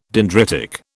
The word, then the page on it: dendritic: British English